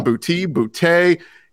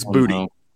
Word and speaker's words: Boutte: Everybody has said Kayshon Boutte. He said this week, talking with reporters, it's Booty. Booty. He said it's pronounced Kayshon Booty.